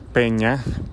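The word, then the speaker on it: Penya: Esto es Peña, Peña escrito con NY, aunque se pronuncia como las peñas latinoamericanas, y la pieza es False Prophets.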